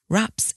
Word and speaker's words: wrap: I do know someone who wraps in tin foil, but for most people, wrapping Christmas presents in nice Christmas paper is the norm. So there's the verb to wrap, W-R-A-P. Notice that silent W there.